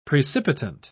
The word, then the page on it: precipitant: British English